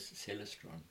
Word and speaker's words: Celestron: And they said to him, no, our name is Celestron. – Right, right. – So I've always remembered and called it Celestron. – Because when you say it like that, you can see why. It's yes, you Celestron. Can see yes, why. Yeah. Exactly. So Celestron. So maybe people will learn now to say Celestron.